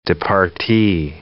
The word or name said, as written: departee